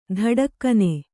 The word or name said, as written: ಥಟಕ್ಕನೆ